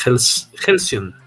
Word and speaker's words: Hellcyon: Siempre he tenido problemas cómo se pronuncia el, el título de la novela gráfica de Lucas. Helsion. Es Helsion. – Se escribe este, H-E-L-L-C-Y-O-N.